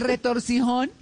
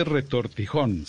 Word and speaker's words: retortijón: Bueno, ¿y el retorcijón? – Bueno, yo no sé si a ustedes les, les, eh, les ha pasado que no se dice retorcijón, se dice retortijón.